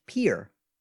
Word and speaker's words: Pierre: Despite how it's spelled, what state's capital name is properly pronounced Pier?